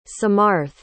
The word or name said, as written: samarth